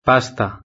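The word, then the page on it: pasta: European Spanish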